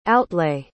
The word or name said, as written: outlay